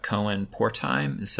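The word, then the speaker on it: Cohen-Portheim: Cohen Pörtheim. Am I pronouncing his name correctly? – You are.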